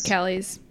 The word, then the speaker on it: Callies: How do you say this last name? Is it Callies? – I believe it's Callies. Sarah Wayne Callies. Yeah.